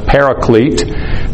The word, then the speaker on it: paraclete: Parakletos is the Greek. It's often pronounced paraclete.